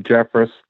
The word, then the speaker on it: Jefferis: My name is Donald Jeffress.